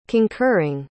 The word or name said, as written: concurring